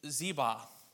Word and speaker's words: Ziba: And there was of the house of Saul a servant whose name was Ziba. And that sounds too close to zebra, but that's how it's supposed to be pronounced, according to what I heard.